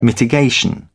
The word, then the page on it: mitigation: British English